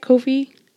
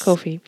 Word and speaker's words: Ko-fi: Am I saying that right? Ko-fi? – Yes. – Ko-fi.